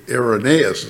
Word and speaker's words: Irenaeus: Anyway, here's what Irenaeus, I used to call Irenaeus Irenaeus, and some people do. But I hear scholars say Irenaeus is the proper pronunciation.